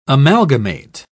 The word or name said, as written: amalgamate